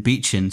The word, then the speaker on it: Beetschen: Ah, so it's Alex, spelt the usual way, and then Beechen, spelt BEETSCHEN.